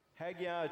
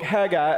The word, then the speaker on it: Haggai: I told them, because I was raised in Mississippi, we were taught to add a letter or a syllable to every word. Chicago. Walmarts. And here, Haggai, the extra syllable that is added.